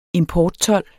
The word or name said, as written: importtold